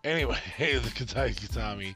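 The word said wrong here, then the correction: Kata-gatame: Oh, it's spelled with a G. See, I was looking it up. So it's Katagatami.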